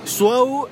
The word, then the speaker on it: Suau: Suau S-U-A-U. Es como wow, pero suau. Suau.